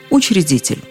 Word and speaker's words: учредитель: Конечно же, первая буква «е» там совершенно лишняя. В слове «учредитель» после «ч» нет гласных. И читается и пишется «учредитель».